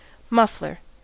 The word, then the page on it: muffler: American English